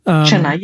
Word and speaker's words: Chennai: And, and the same news to come out about Chennai, am I saying that right? – Chennai, yeah.